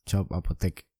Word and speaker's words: Apotheke: Me parece que has pronunciado mal. Yo soy un poquito de alemán y es Shop Apotheke. – Apotheke, Bueno. no, es como en inglés, que el, alemán pronuncia muy parecido a, nosotros. Entonces sería Shop Apoteque.